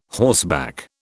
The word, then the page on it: horseback: British English